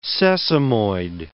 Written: sesamoid

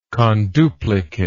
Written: conduplication

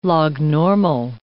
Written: lognormal

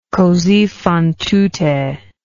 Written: cosi fan tutte

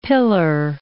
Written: pillar

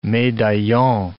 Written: medaillon

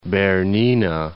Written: Bernina

